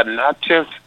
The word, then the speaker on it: Natchez: Now, you were, grew up in, how do you pronounce it, Natchez in Mississippi? – Yeah, Natchez, Mississippi.